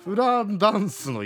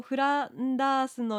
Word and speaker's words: フランダース: フランダンスの犬。 – わお。 – どうしよう。フランダンスの犬ね。 – ちゃんと書いてますよ。フランダースの犬、イギリスの女流作家ウィーダー。